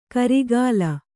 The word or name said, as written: ಕರಿಗಾಲ